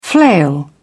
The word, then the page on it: flail: British English